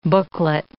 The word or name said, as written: booklet